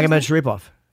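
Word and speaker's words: Magomedsharipov: Magomed Sharipov. Correct? – Yes.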